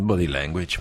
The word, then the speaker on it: body language: Body language, così? Come si pronuncia? – Body language, vabbè.